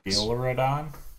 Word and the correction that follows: Duraludon: Dioladon. – Yeah, that sounds good. – Duraludon.